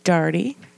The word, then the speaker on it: Darty: My second grade teacher, Miss Darty, which rhymes with party.